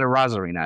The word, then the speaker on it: Arozarena: I can't say his name. Arozarena. Yeah, he's at 27. – Arozarena, sir. Let's put respect on Randy's name. – I was close.